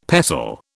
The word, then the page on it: pestle: British English